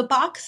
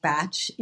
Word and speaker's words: Bach: So the box center or the batch center, it's actually pronounced batch in the UK. – Mm-hmm. Okay. – Either way is fine.